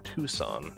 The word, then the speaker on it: Tucson: And Eggs Benny said, I'm pretty sure it's pronounced Tuscan. – Tuxen. – It's not. And the C comes before the S, so it would be... It's Tucson.